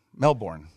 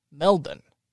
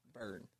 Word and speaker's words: Melbourne: Can you say it right? – Melbourne. – Don't say it like that. – How do you say it? – Melbourne. – Melbourne. – Thank you. – Burn. – Burn. – Burn. – Burn. – But it's spelled Melbourne.